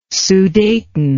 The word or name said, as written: sudeten